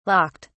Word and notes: locked: British English

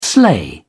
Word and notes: sleigh: British English

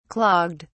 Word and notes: clogged: British English

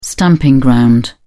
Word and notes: stamping ground: British English

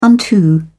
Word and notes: unto: British English

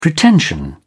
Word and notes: pretension: British English

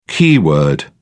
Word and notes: keyword: British English

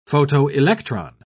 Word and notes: photoelectron: British English

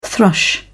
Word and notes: thrush: British English

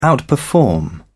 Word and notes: outperform: British English

